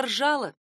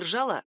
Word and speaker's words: ржала: Еще один аналогичный глагол — ржать. Лошадь громко ржала, а не ржала.